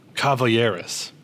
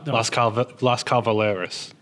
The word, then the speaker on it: Las Calaveras: No, I know what you do. Caballeros. That's what you do. You flip the V and the L. – Oh, Las Calaveras.